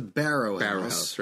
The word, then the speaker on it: Barrow: Barrow House. B-A-R-R-O-W. So Wheelbarrow. I think it's pronounced the Barrow House, right?